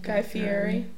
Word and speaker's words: Guy Fieri: my next one is Guy Fieri. Is that how you pronounce his name? – Guy Fieri.